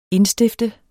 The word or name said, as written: indstifte